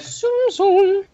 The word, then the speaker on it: Tsum Tsum: Did you see the picture of that Tsum Tsum, or however you pronounce it? That Tsum Tsum.